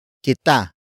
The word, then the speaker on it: kita: Word for word it's ibibigay kita ng regalo.